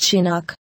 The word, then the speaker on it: Chinook: They are often caused by a phenomenon called a Chinook, pronounced Chinook.